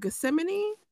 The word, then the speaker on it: Gethsemane: When he was in the Garden of Gethsemane, did I say that right? I believe so. – Yes. Yeah. You did.